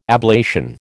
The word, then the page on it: ablation: American English